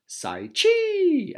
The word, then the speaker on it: Psyche: Thanks to Andre Psyche for supporting the Getting to Know You pod. My band been down since almost day one. Search him up, listeners. Andre Psyche, or Psyche, as it's spelled phonetically, P-S-Y-C-H-E.